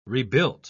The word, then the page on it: rebuilt: British English